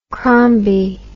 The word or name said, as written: crombie